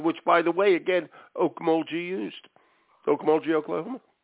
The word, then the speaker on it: Okmulgee: Okmulgee, you're right, it is a hard G.